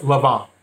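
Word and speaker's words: Lanvin: And it's L-A-N-V-I-N. And it's pronounced Lavin. The N is silent in French.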